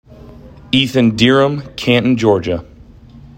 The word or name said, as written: Ethan Dirrim